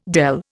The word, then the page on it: dele: Indian